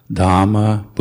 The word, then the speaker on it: Dhamma: And try to pronounce all the H's, like I said, as I said before, Dhamma, Buddha, and Sangha.